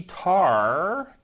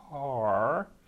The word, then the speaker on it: Tahr: Trusty Tar, T-A-H-R, Tar, sounds like a Klingon animal. That'd be a Targ. T-A-R-G, Targ. This is a trusty Tar, T-A-H-R, okay?